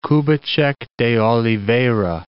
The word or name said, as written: Kubitschek de Oliveira